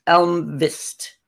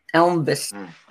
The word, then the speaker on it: Almqvist: I even looked up the pronunciation. It's Almqvist, I think is how they say it. Almqvist.